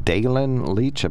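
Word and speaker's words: Daylin Leach: Dalen Leach, I believe, is the correct pronunciation.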